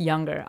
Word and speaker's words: Junger: his last name is J U N G E R, but it's pronounced Younger. I'll link that in the show notes for you.